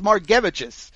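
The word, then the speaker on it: Margevicius: I can't believe it's pronounced that way. I had to look this up. It's Margevich's.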